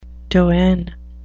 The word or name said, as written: doyen